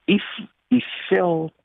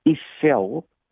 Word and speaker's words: Eiffel: Aan die ander kant is die if Eiffel toring, dis nou die Franse uitspraak. Ek het dit met ehm uh, Ignatius gesels oor die uitspraak. Dis die Eiffel toring in plaas van die Eiffel toring wat van staal gebou is, waarskynlik die mooiste staal toring in die hele wêreld.